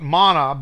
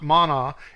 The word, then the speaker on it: man: They call it manna, but it's not manna. It's just man. M-A-N, spelled like the word man. But the A has an ah sound to it in the Hebrew. That's it's not an ah, it's an ah. So it's man.